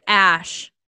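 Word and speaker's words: Æ: Oh, the AE is pronounced Ash.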